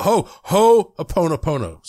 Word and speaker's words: Ho'oponopono: And by the way, a guy who listens says, you're not saying it right. It's supposed to be Ho'oponopono. You got to stress that Ho'oponopono.